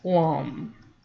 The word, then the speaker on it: lam: The mantras for each chakra are spelled with an A, but more properly pronounced with a U. For example, L-A-M, lam, for the root chakra, when chanted, sounds more like L-U-M, lum.